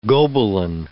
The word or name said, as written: Gobelin